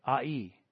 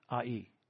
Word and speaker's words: Ai: Israel had great confidence that the city of, I'm going to say it, Ai, is how it's pronounced, Ai, was going to be another victory for them.